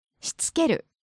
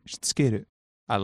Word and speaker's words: しつける: しつける。 – Just break that down. – しつける。 – しつける。 – うん。 – しつける。I like that word.